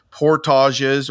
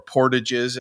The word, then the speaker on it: portages: portages or portages, as they say with the English pronunciation of the French word, that can take multiple days.